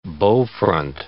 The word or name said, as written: bowfront